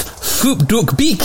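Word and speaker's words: Phuc Dat Bich: His name is actually pronounced Phup Duc Beek.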